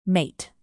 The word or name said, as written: mate